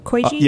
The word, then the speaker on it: koji: Koji.